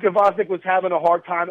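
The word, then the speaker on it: Gvozdyk: Gvozdek, or some people say Vozdek. I'm going with Gvozdek.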